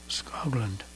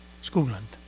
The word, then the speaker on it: Skoglund: And Lars Skoglund on drums and vibraphones. Skoglund. Probably better pronunciation.